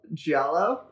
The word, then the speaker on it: Giallo: Giallo.